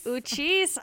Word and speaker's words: Uchis: This is Ride and Round by Kelly Uchis.